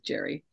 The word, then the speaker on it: Gerry: It's actually pronounced Gary, but everyone says Gerry.